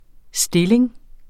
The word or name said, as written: stilling